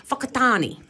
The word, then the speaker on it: Whakatane: Whakatane, but it's spelled WH. – WH. Oh, well. – Correct. And I gave the correct pronunciation, Whakatane. – Ah, well done. – Lyndon, how's that spelled? – B-U-L-L-S-H-I-D.